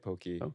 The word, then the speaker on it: poke: How do you say it? Poke? – Poke. – Poke. Yeah, it's like a poke bowl. – Poke bowl. – Yeah. – Poke, pokey. – Pokey. – Tomato. – Never heard poke.